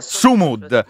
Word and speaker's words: Sumud: Ecco, l'altra cosa che ti volevo proprio chiedere, la stavi già anticipando tu, è questa questione della, spero di pronunciarla bene, Global Sumund Flotilla. – Sumud, ecco, me lo sono segnato, Sumud, sbagliato.